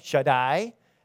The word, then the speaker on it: Shaddai: When you have, in Hebrew, you have a lot of the words ending in I or A-I, but it's pronounced I, Shaddai. It means my.